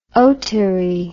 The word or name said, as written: otary